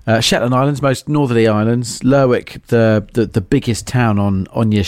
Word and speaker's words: Lerwick: Is it Lerwick or Lerwick? – Lerwick, if you're talking Shetland Islands.